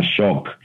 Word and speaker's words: Choc: Un perfume muy, muy desconocido que siempre lo menciono es un perfume de Pierre Cardin que se llama Shock. C-H-O-C, que quiere decir shock, pero se escribe como chocolate.